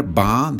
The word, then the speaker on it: va: Remember, va, the, the V is pronounced B, so ¿cómo va la vida?